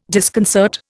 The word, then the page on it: disconcert: Indian